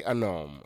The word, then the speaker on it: homme: Everybody say un homme. Repeat after me, un homme. Un homme, U-N for un, then homme is H-O-M-M-E, silent H, H-O-M-M-E, un homme.